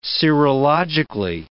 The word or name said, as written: serologically